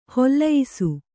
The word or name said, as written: ಹೊಲೆಯಿಸು